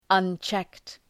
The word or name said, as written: unchecked